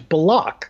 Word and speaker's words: Bullock: But he also would correct himself and say, no, actually, Reggie has requested that his name be pronounced the way it is pronounced, which is Bullock.